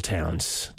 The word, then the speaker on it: Townes: And we're going to start off with this song from the artist Tennille Towns. Her album, it's brand new. It's called The Acrobat. And yes, indeed, her last name is spelled T-O-W-N-E-S, traveling in high cotton there. Tennille Towns.